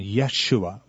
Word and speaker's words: Yeshua: First of all, there is no J sound in Hebrew. It's Yah. We say Jesus, but it's not a hard J sound. Yeshua would be his name.